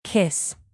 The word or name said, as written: kiss